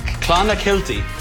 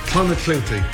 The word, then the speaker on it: Clonakilty: Clonakilty. – Clonakilty. – Clonakilty, thank you.